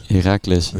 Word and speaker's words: Heracles: Heracles, ja.